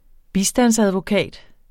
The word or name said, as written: bistandsadvokat